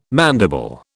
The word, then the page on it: mandible: British English